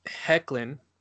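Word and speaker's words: Hoechlin: And um, Hecklin, as it's pronounced, thank you, Matt, uh, did a really good job, you know, giving that.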